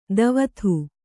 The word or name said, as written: ಧೋವತಿ